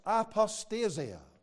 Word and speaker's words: apostasia: But the original word is pronounced something like this, apostasia.